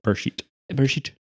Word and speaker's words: Bereshit: Bereshit.